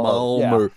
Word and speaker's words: Malmö: Malmö. How do you say it? How do you say it? – Malmö. – Malmö. – Yes, that's good. – Malmö. – Yeah, in my dialect.